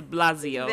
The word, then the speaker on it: de Blasio: The mayor, Bill de Blasio, That's how you say it? – Yeah. – Bill de Blasio.